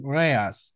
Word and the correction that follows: reais: I said it wrong. How do you say it? – Hey, ice.